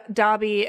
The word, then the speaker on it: Dobby: So with the goblins, there's a whole thing with Dobby, is that how we pronounce his name? – That is how we pronounce his name. – So like Dobby, and like there's all kinds of really like great sort of analyses of this on the internet, of course.